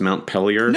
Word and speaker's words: Montpellier: Is it pronounced Montpellier? – No. I was just kidding. No. Yeah. I think he had the right pronunciation.